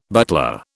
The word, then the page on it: butler: British English